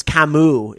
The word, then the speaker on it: Camus: And Shane says it's Camus is how you say it. Oh, bummer. That's not how it's written like, though. C-A-M-U-S.